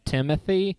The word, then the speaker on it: Timothée: It's iffy because I think that probably is the right way to say it, but people say Timothy, including Army Timothy. – Hammer, Greta and Gerwig, Timothy. And Timothée Chalamet himself. But like other people will pronounce it your way.